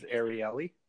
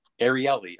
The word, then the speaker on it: Ariely: The way I've heard it pronounced is Ariely. – Ariely.